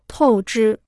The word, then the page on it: 透支: tòu zhī